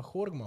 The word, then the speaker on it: Horgmo: In pa Horgmo. Ja, bravo. Ne, lepo si izgovoril. – Ja, ja. – Ja, Horgmo tudi.